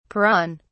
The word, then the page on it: peron: British English